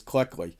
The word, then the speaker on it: Cleckley: am I saying that right? It's Cleckley, in the classic psychiatric text,